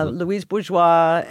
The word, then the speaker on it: Louise Bourgeois: Is that how you say her name? – Yeah, Louise Bourgeois.